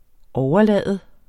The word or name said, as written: overlade